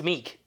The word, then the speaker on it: Miek: It is Mike, but it's spelled, like, M-I-E-K. – Oh, okay. – But, yeah, for Meek. It's Meek. – Meek. Is that how it's pronounced? – Meek.